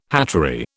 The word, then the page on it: hatchery: British English